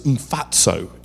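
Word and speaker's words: Mphatso: Her name is spelt, Mpatso is PH, so it's Mfatso in English.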